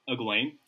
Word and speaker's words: Egwene: Number two is, and please correct me if I'm wrong pronouncing it, Egwene? – Egwene. – Egwene? Okay, I wasn't too far off.